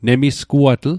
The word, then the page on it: nemisguatl: Mi'gmaq